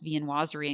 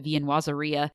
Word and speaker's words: viennoiserie: I think Sue mispronounced viennoiserie and called it viennoiseria.